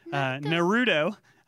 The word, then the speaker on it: Naruto: Naruto.